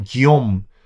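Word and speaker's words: Guillaume: 야, 이거 지금 너무 힘이 세진 윌리엄, 우리 프랑스 발음으로는 기욤.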